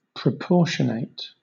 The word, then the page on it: proportionate: British English